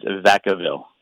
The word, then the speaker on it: Vacaville: The correct pronunciation is Vacaville. – Oh. Vacaville. Strangely enough, yeah. I received a tremendous amount of feedback on that. And Vacaville it is.